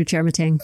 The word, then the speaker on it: Chermatang: I've never bust this out on the show, but the proper pronunciation, like if you were in Ghana, is Chermatang, like you just threw down.